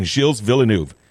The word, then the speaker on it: Gilles Villeneuve: Jacques and Gilles Villeneuve.